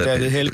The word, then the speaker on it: David Held: Og det er godt nok et sted, hvor du så citerer en David Held, skal vi sige det? Er det korrekt udtalt? – Ja, David Held kalder jeg ham.